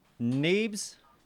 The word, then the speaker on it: Neebs: Neebs. – Neebs. Can you spell it? – N-E-E-B-S. Yep. – N-E-E-B-S.